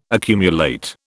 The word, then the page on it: accumulate: British English